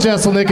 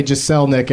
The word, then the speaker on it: Jeselnik: Can we stick with Jeselnik? It took me forever to learn how to pronounce that right. I always wanted to say Jeselnik every time I.